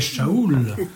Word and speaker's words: Saoul: C'est-à-dire que le grec traduit Saoul, Saoul, mais parce qu'ils ne savent pas dire ch, hein, ils ne savent pas le prononcer correctement. Sinon, ils diraient Shaoul, ça aurait été encore plus juste.